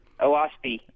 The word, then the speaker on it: Oahspe: If I even remember, OASPE? – OASPE. – Which is spelled O-A-H-S-P-E.